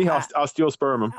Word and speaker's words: osteospermum: An osteopernium? – Nearly, osteospermum.